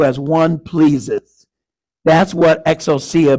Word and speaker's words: exousia: The Greek word of authority is exousia. It's spelled E-X-O-U-S-I-A.